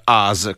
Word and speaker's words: US: Nel 1983 Steve Wozniak di Apple organizza il secondo US Festival, che si pronuncia AS come noi, alla Glen Helen Regional Party San Bernardino in California, con l'intenzione di farlo diventare il Super Bowl del rock.